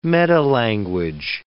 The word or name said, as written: metalanguage